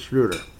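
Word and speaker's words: Schröder: It's Dennis Schroeder, isn't it? It's Schroeder. Yep.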